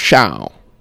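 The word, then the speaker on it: Chão: Yeah, I feel like most people think it's Fogo de Chao, though. – Yeah, I mean, I think that's the common like American pronunciation. – It's Chao.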